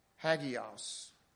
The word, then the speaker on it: hagios: The Greek word for holy is hagios. Hagios. It's spelled H-A-G-I-O-S.